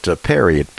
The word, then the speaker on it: Pierre: Normally, we don't sell books here, but it's called The War on Ivermectin, and it's by Dr. You pronounced it Perry, P-I-E-R-R-E, and then Corey, K-O-R-Y.